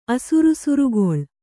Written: ಅಸುರುಸುರುಗೊಳ್

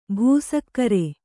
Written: ಭಾಸ್ಕರಿ

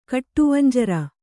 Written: ಕಟ್ಟುವಂಜರ

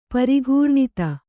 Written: ಪರಿಘೂರ್ಣಿತ

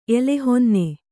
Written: ಎಲೆಹೊನ್ನೆ

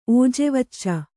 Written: ಓಜೆವಚ್ಚ

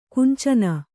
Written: ಕಾಂಚನಿ

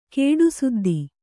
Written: ಕೇಡುಸುದ್ದಿ

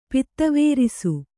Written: ಪಿತ್ತವೇರಿಸು

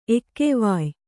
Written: ಎಕ್ಕೆವಾಯ್

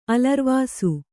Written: ಅಲರ್ವಸೆ

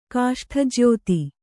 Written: ಕಾಷ್ಠಜ್ಯೋತಿ

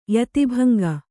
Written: ಯತಿಭಂಗ

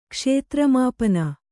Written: ಕ್ಷೇತ್ರಮಾಪನ

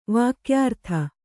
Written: ವಾಕ್ಯಾರ್ಥ

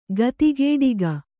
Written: ಗತಿಗೇಡಿಗ

